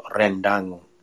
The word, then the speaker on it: rendang: So that could have changed, but it was rendang. When you pronounce an R in Indonesia, you roll the R. So rendang, and rendang is a beef that is cooked in.